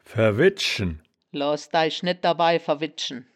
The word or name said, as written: verwedschen